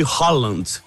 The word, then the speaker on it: Haaland: Eu tava falando do calor, tava lendo nos jornais da Noruega, eles chamam lá o Haaland de Holland, né? Como se fala em norueguês.